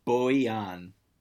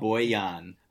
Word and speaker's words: Bojan: Real quick, what's your over under for Bojan Bogdanovic game two? Over under 17.5 points. – Wait, before you answer that, it's Bojan. – Oh, Bojan.